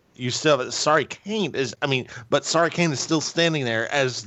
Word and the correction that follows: Kairi Sane: Well, I'm sorry, did I pronounce your name wrong? – You not even pronounce it, you used the wrong letters. Kairi Sane. – Kairi Sane.